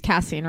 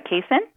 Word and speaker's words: casein: No, I was just saying my husband is allergic to it's casein, right? That's how you pronounce it? – Right, casein.